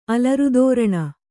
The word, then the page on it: ಅಲರುದೋರಣ: alarudōraṇa